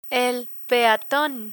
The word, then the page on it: peaton: Spanish